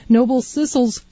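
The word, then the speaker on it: Noble Sissle: When we're all Sidney Bechet tonight on The Swing Years and Beyond with Noble Swissels. I didn't say that right. Noble Sissels Swingsters.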